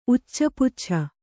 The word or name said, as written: ಉಚ್ಚಪುಚ್ಛ